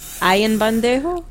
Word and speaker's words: Ayanbadejo: A-Y-A-N-B-A-D-E-J-O. Bandejo. Ayanbadejo? – Ayanbadejo. – That's what I'm going to go with. – See, cause you gotta like separate it. So it's Ion Badejo.